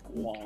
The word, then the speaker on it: squonk: It's squonk. It's pronounced squonk.